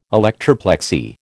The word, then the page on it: electroplexy: American English